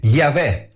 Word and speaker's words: Yahweh: Dice quindi io sono, Heye, e poi introduce il tetragramma, YHWH, che talvolta sentiamo pronunciare proprio come Yahweh.